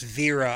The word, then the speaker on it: Vera: By the way, I believe her name is pronounced Vera. I looked it up. Vera Farmiga.